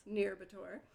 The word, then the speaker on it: Nyírbátor: Nyírbátor.